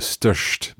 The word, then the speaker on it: störst: And our vowel sound is changing there. – Yes, and it's that sh sound again. Is that so störst. – Störst. – So it's almost it's a much more shorter ö sound.